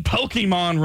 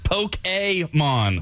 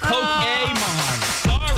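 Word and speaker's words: Pokémon: Meanwhile, all of this talk is upsetting some nerds in the audience, like Trevor, who wrote in that says, you're saying Pokemon wrong. It's actually pronounced Poke-A-mon. – Whatever. – So it's Poke-A-mon.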